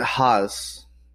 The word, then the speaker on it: Haas: Haas.